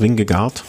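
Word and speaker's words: Vingegaard: Primo Roglic vor Jonas Wing, wie spricht man das? Wingegaard, denke ich mal.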